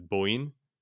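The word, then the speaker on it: bo (母): And haha, I think, is pronounced usually, bo.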